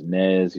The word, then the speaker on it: Nez: My name is Nez. It's spelled N-E-Z.